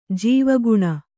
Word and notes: ಜೀವಗುಣ: jīvaguṇa

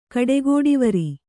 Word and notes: ಕಡೆಗೋಡಿವರಿ: kaḍegōḍivari

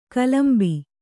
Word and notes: ಕಲಂಬು: Kannada

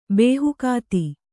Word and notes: ಬೇಹುಕಾತಿ: bēhukāti

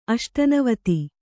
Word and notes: ಅಷ್ಟನವತಿ: Kannada

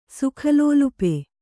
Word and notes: ಸುಖಲೋಲುಪ: kannada

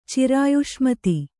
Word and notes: ಚಿರಾಯುಷ್ಮತಿ: Kannada

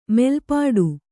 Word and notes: ಮೆಲ್ಪಾಡು: melpāḍu

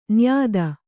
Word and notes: ನಿಯತಿ: niyati

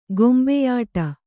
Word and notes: ಕೊಂಬೆಯೊಡೆ: kombeyoḍe

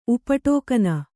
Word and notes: ಉಪಟೋಕನ: Kannada

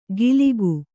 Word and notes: ಗಿಲಿಗು: giligu

